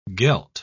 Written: gelt

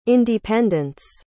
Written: independents

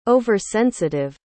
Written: over-sensitive

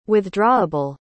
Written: withdrawable

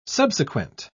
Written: subsequent

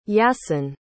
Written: Yassin

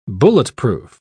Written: bulletproof

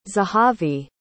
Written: zehavi

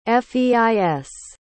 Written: feis

